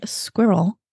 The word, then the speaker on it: squirrel: I'm saying squirrel. Because it's a two-syllable word. And he's like, it's squirrel. And I'm like, it's two syllables. It's not squirrel.